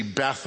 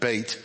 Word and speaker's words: Beit: Now you may see Beth up there, but it's pronounced, when you're talking about the Hebrew alphabet, Beit.